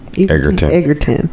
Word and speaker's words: Egerton: Egerton. – He. – Taron. – T-A-R-O-N.